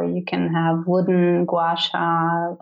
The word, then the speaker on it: Gua Sha: The Gua Sha. Oh, you can order that online. – Okay. – It's you spell it G-U-A-S-H-A.